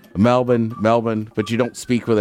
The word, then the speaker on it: Melbourne: I do feel weird saying it because I feel like you're trying to sound pretentious, but the thing is, if they call it Melbourne, then that's the way it's pronounced.